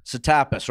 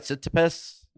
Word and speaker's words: Tsitsipas: You pronounce his last name Tsitsipas, right? Tsitsipas. – Tsitsipas or something. Tsitsipas. – Yeah, right.